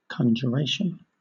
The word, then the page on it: conjuration: British English